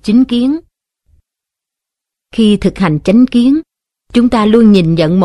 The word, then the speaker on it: chánh kiến: Chánh kiến đôi khi cũng đọc là chính kiến, với ý nghĩa là quan niệm, cách nhận hiểu vấn đề một cách chân chánh.